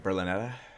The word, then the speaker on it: Berlinetta: Is it the Berlinetti? Berlinata? – Berlinetta.